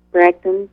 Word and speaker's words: Bragdon: That's E-M-M-A, and then Bragdon, B-R-A-G-D-O-N.